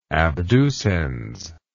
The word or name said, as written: abducens